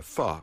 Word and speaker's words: pho: There's a soup, a wonderful soup, called pho, which rhymes with duh, and it's spelled P-H-O, which for an English speaker seems odd.